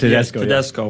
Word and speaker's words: Tedesco: And, of course, we can't forget the man, the myth, Domenico Danesco. I think I'm pronouncing Tedesco. – It right. – Tedesco. – Yeah. I'm pronouncing it right.